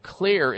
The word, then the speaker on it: Xlear: So we want to take something called Clear Sinus Care. And Clear is spelled with an X. The X stands for xylitol. X-L-E-A-R. Clear Sinus Care.